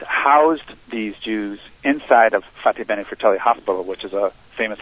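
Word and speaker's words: Fatebenefratelli: Fateh Bene Fratelli.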